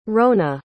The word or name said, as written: rhona